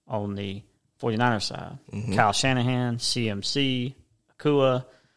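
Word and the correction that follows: Aiyuk: Is that how you say his name? Akua, that new? – Ayuka. – Yeah. I've been saying it wrong.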